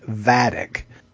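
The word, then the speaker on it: Vadic: Because I was calling her Vladik last episode, and her name is actually Vadik.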